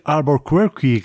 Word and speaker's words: Albuquerque: Quizá deberíamos decir Albuquerque, que es como lo pronuncian por allí en los Estados Unidos, dado que nos referimos a la ciudad de Nuevo México, que imagino que también tendremos derecho a llamarla Albuquerque, ¿no?